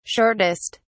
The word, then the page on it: shortest: British English